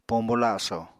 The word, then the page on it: pombo laso: Apatani